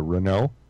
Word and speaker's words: Renault: I think Renault comes from France too. – Sounds like it. – Spelled Renault. – Yes. – Exactly. Anytime you don't pronounce a T, it's probably French.